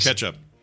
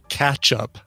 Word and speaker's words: ketchup: Ketchup. – Yeah. – Ketchup. Is that wrong? – I know. He claims that you pronounce it catch-up. – Catch-up. – Yeah. – No, I'd say ketchup.